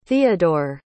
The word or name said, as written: theadore